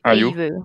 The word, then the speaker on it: Ayew: Eu acho que assim, é, o Zaha é muito importante para o time do time do Crystal Palace, mas o Jordan Ayew, como é que fala, gente? – Ayew. – Ayew.